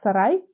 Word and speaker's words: Сарай: Потому что раньше, насколько я, если правильно поняла, э, имя ее произносилось как Сарай. Сарай.